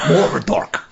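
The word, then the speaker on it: Mourdock: No, you have to pronounce it like everybody pronounced Mordor in The Lord of the Rings, kind of with a weird Scottish accent. Murdoch.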